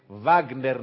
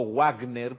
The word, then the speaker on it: Wagner: Igual que se dice Wagner, no Wagner, por favor. Y no es Richard Wagner, es Richard Wagner, alemán.